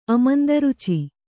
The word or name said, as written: ಅಮಂದರುಚಿ